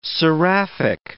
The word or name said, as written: seraphic